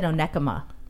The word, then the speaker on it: Onekama: O-K-E. – Okay, what? – O-K-E-M-A. Is that how you spell Okama? – It? – Okama? – Okama? – No. I said Onekama. – Onekama. – Oh, yeah, you really, you added some syllables in that one that weren't there.